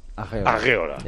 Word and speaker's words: Areola: No es Areola, ¿no? – No, bueno, los franceses lo pronuncian así, Alphonse Areola. – Areola. – Es como Courtois, pero Areola.